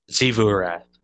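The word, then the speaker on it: Xivu Arath: And I didn't know this before, but how the fuck do you say that? X-I-V-U Arath. – Zivurath. – Zivu Wrath. Yeah, that's how you say it.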